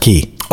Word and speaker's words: chi: Well, it's chi is the, it's actually pronounced ki.